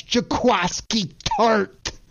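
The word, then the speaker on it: Jaquiski Tartt: KP, I got to help you with that pronunciation because it's one of the best names in all sports, and that's Jaquaski Tart.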